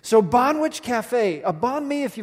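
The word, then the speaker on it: Banh Wich: Okay, for the rest of you, you spell it B-A-N-H, which, W-I-C-H. So it's like banh mi, which is a Vietnamese sandwich put together with sandwich, Bonwich Cafe.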